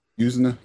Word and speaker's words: Yuzna: Yuzna? – Yuzna, yeah. – I guess that's how you pronounce it.